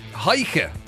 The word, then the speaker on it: Heike: Heike. Would you pronounce it like that? H-E-I-K-E. – That's how I pronounce it, but that doesn't stand for very much now.